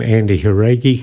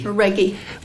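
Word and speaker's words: Andy Jauregui: I'm glad you pronounced that name, Andy Haragi. – Haragi.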